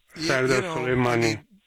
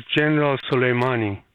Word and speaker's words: Soleimani: Soleimani. – I'm sorry, please give me the correct pronunciation. – Yeah, his name is General Soleimani.